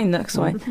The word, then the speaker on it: Knox: Knox? – Knox.